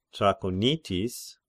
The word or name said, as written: Trachonitis